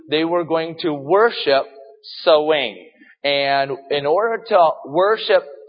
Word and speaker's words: Samhain: All right, it's not spelled Samhain; it's spelled S-A-M-H-A-I-N, but it's pronounced Samhain.